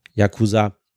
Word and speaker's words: Yakuza: Ähm, Yakuza kommt ein bisschen mehr an das Japanische dran, aber eigentlich sagen die Japaner Yakuza. Kein großer Unterschied zwischen den drei Silben, aber das ist eigentlich die Aussprache.